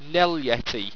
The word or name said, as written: nellyeti